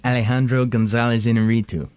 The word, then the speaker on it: Alejandro González Iñárritu: Alejandro González Iñárritu.